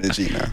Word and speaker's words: Regina: Okay, there's an American pronunciation. Regina, no? – Regina. – Yes. China, there you go.